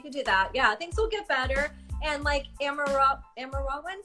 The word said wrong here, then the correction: Amora: did I say that right? Was saying you'll have fun at the video shoot. – Oh, yeah, Amora.